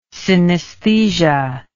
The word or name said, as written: synaesthesia